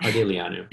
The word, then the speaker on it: Ardeleanu: Sorinne Ardeleanu.